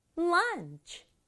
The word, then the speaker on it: lunch: lunch. 우리말로도 음식점에 가서 런치 세트 이런 거 친구들 본 적 있죠? 우리말은 두 박자인데 영어는 lunch, 치 이렇게 하지 않고 lunch 한 박자로 발음해요.